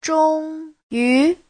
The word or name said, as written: zhong1yu2